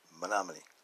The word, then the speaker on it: Menominee: She works a lot with Native Americans, and she's very close friends with some of the tribal elders of the, if I say it right, Menominee. I said it right.